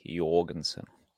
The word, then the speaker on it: Jorgensen: Jorg, I think I think it's a soft J, Jorgensen.